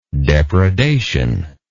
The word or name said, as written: depredationist